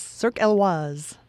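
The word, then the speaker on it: Cirque Éloize: Yes, Cirque Elwaz.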